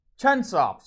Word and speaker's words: Chunsoft: The dude went on to make his own company, Chunsoft, and I'm pretty sure that's how it's pronounced. Yeah, it is. Chunsoft, sorry.